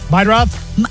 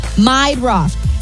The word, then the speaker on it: Meidroth: Chase Murdaugh? The White Sox guy? – Meidroth. – How do you say that? – Meidroth.